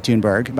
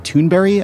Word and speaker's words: Thunberg: And today, Greta Thunberg, by the way, I'm not pronouncing that correctly. It's more like Greta Thunberry, I think, the way that she says it, but I'm not Scandinavian, so I'm not going to try.